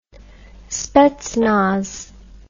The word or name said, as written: spetsnaz